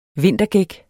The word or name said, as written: vintergæk